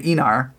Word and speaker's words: Aenar: They're called the Aenar, and so I kind of want to call them the Aenar, but yeah, it's the Aenar, even though it's spelled with an A at the beginning, and.